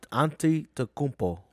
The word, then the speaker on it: Antetokounmpo: Antetokounmpo. – Yeah. Antetokounmpo.